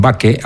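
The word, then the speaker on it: Bacche: Así que vamos ya con esa pieza que se titula precisamente, bueno, ellos dicen Bache, aunque bueno, en latín habría que pronunciar de otra manera, pero bueno, se ha tomado un poco la pronunciación italiana para estos casos.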